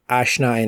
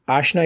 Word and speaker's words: Aashna: That was Rachel, Aashna, and Ellie. I realized when I was editing the podcast that I mispronounced Ashna's name incorrectly a couple of times during the podcast. Aashna, if you're listening, I apologize for that.